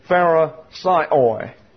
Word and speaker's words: Pharisaioi: Pharisaioi is the way their Greek pronunciation is.